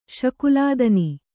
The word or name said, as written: ಶಕುಲಾದನಿ